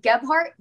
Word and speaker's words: Gebhardt: Oh, yeah, Nicole, N-I-C-O-L-E, Gebhardt, G-E-B-H-A-R-D-T.